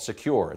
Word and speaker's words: Sekur: That's why you need a new privacy and cybersecurity application tool called Secure. It's spelled S-E-K-U-R.